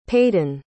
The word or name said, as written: paden